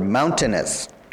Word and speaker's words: mountainous: It's mountainous with the accent on the first one, and the tennis becomes weaker.